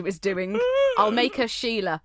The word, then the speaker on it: Shelagh: Sheila. S-H-E-L-A-G-H. Sheila.